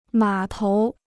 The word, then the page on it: 码头: mǎ tóu